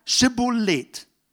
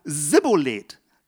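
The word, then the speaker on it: Schibbolet: forderten sie auf, sagt doch einmal Schibolet. Sagte er dann Sibolet, weil er es nicht richtig aussprechen konnte, ergriffen sie ihn und machten ihn dort an den Furten des Jordans nieder.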